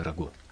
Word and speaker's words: рагу: поэтому на оригинале будет правильнее сказать «фламандское рагу». – А что ставится? Ударение? – Да, ударение. Ударение во французском языке обычно на последний слог.